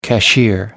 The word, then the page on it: cashier: American English